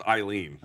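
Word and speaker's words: Ailin: It's pronounced Eileen, okay. uh, but it's spelled A I L I.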